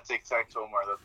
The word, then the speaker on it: TicTacTOmar: Yeah, Tic-Tac-To-Omar.